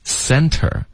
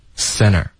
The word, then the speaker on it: center: 可是后面呢 interfere center 这两个字呢，在美式发音中特的音几乎肯定会省略 interfere center，因为这个舌尖顶上颚发 n，再爆一个 t 有点别扭，就直接连发 n 过去了。